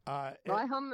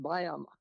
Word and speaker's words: Byhem: Byhem is fine. Byhem is fine, but most people don't even bother to pronounce the H, so it's just Byhem.